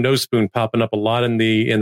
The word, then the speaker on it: Nospūn: Among the things that you usually take care of before maybe you start officially recording is making sure I got the pronunciation correct. It's Nospūn? – That's correct. – Okay. The line over the U is indeed functional.